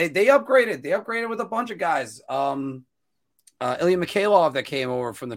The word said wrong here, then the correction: Mikheyev: Yeah, Mikheyev. Sorry, I added an extra syllable. Mikheyev. But also the guy from Russia that they got, whose name is slipping my mind right now.